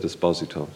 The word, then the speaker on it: Esposito: Mrs. Marshall always said Esposito, so mm-hmm. I think it must have been pronounced that way in Dublin.